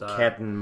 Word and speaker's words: Carlton: I think it tips it towards the Dogs rather than Carlton Blues or Catton more correctly pronounced.